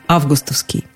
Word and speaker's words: августовский: Там нам советуют говорить с ударением на первом слоге – августовский.